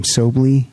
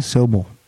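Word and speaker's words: Sobule: Sobley? – Sobel.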